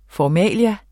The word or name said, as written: formalia